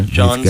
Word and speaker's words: jawn: John spelled J-A-W-N. It's a Philadelphia.